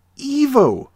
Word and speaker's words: Ivo: It's supposed to be pronounced Evo.